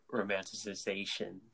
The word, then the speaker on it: romanticization: Oh, I've never heard of romanticization. I'm pretty sure I messed that word up. – Oh, no, you said it right.